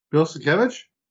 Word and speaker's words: Bill Sienkiewicz: Bill Sienkiewicz? – Yes, that's how you say his name.